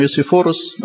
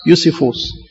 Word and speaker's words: يوسيفوس: في واحد فيلو اسمه فيلو، يعني فيلسوف يهودي، ويوسيفوس، أو زي ما احنا متعودين تملي نقول اسمه يوسيفوروس، بس برضه هو يعني صح، يعني النطق صح بتاع يوسيفوس.